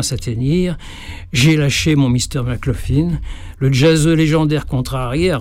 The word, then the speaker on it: McLaughlin: John McLaughlin, dans les bronches, pour rectifier ma prononciation de son joli nom. Ça se prononce comme ça s'écrit, effectivement.